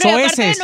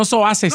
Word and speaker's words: soeces: Estoy usando palabras zoásisis. – Bueno, soeces. – No es... ¿Cómo se dice? – Soeces, no zoases.